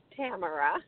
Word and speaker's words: Tamara: I am doing well, and it's Tamara.